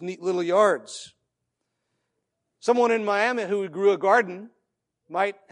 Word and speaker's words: Miami: Looks like Miami, Florida. But in Oklahoma, we don't know how to pronounce things correctly, so we say Miami.